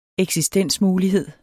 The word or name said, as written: eksistensmulighed